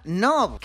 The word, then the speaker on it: KNOB: Canov, que se pronuncia NOV, que la K eh, en inglés no se pronuncia, ya lo sabéis, ¿eh?